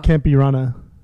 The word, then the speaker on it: Campirana: How do you pronounce that second word? – Cumbia. Uh, com- Campirana.